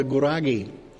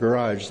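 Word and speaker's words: Gurage: And when we went to Ethiopia, we preached the gospel among a group of people who had never heard about Jesus before called the Guragi. That's not garage, that's Guragi, the way you pronounce it.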